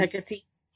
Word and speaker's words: Hecate: Hecate.